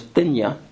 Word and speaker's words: Nthenya: Dondo, one of Charles's daughters, is spelled N-D-O-N-D-O. Several of the grandchildren, including Anne and Esther, her name is Thenya, is spelled N-T-H-E-N-Y-A.